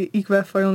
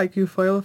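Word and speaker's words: iQFOiL: Uh, e, můžeš to vyslovovat buď český IQ foil nebo IQ foil v angličtině.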